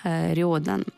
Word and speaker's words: Рёдан: Также поговорим сегодня о том, что особенного в японских комиксах, которые последние, буквально несколько недель на слуху из-за подростков ЧВК Рёдан. Рёдан еще часто тоже называют, в общем, и так, но все-таки правильнее с буквой Ё произносите.